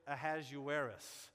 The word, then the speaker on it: Ahasuerus: The English version is Ahasuerus. The Hebrew version of his name is Ahasuerosh.